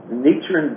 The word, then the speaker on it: Nichiren: Nichiren Buddhism. – Nichiren Buddhism for over 20 years.